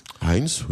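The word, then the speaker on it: Hains: On prononce Heinz. – Heinz, oui, je pense, oui.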